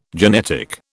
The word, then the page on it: genetic: British English